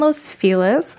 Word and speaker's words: Los Feliz: So I live in Los Feliz. That's how they say it there. You know, whenever I say Los Feliz, I get corrected.